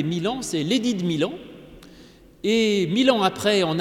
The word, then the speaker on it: Milan: Milan, c'est une ville en Italie, mais ça se prononce comme 1000 ans.